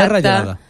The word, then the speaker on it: Serra Gelada: Los problemas por las reclamaciones de los terrenos y la edificación dentro de Serra Gelata, o sea, nunca sé si lo digo bien. – Gelata no, Gelata sería en italiano. – Gelada, o sea, Gelata. Serra Gelada.